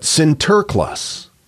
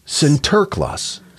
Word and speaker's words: Sinterklaas: It's derived from how the Dutch immigrants of New Amsterdam pronounced his name, not St. Nicholas, but Sinterklaas. Oh. Sinterklaas. St. Nicholas Sinterklaas. – Saint, Sint. – Sint, Terklaas, Nicholas. That's how it sounded to them.